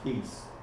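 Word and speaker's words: heis: It's pronounced he's, you know, a primary numeral one, and it means certain plus abundantly man, one another.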